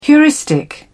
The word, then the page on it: heuristic: British English